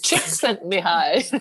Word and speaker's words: Csikszentmihalyi: Mikhail Csikszentmihalyi. – Thank you. Wow. Not my guess. That's not my first 4 seconds. Thank you for that. – Chick sent me high.